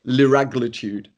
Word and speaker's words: liraglutide: The drug's liraglutide, is that how you say it? – Liraglutide.